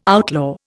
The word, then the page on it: outlaw: Indian